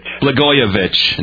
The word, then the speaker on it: Blagojevich: Blagojevich, not an easy name to pronounce.